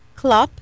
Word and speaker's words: Klopp: Ellen Little is here, and my friend Laura Klopp is here. Yeah. I said it correctly. Like a horse clippity clop.